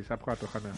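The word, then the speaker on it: SAP S/4HANA: me parece que se dice así, S4 HANA. Lo he dicho bien, ¿no? – sí, SAP 4 HANA.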